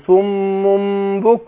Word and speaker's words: صم: فاقول ايه صم قلنا ان الميم المشددة عندنا احنا بننطق ميمين ورا بعض